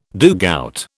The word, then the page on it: dugout: British English